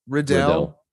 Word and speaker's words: Riddell: Is it Riddell or is it Riddle? Do you know? – Riddell.